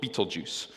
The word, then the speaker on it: Betelgeuse: And there's one, and I quite like the name of this one, it's called Betelgeuse. So that's not how you actually spell it. It's got a bit more of a glamorous spelling to it.